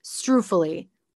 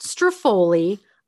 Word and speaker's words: struffoli: This woman is supposed to be running an Italian restaurant and it is pronounced struffoli, or if you are actually from Italy, struffoli. It is not struffoli. I will literally fight anyone that tries to come at me and tell me it's struffoli.